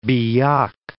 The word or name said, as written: Biak